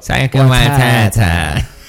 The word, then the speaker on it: Waititi: Taika Waititi.